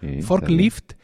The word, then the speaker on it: Forklift: Eso pasa, por ejemplo, con eh, Forklift. No, ya lo he pronunciado mal. Fork, ah, no, lo he pronunciado espectacular. – Forklift Es, es una aplicación que tiene como, como icono una elevadora, ¿no?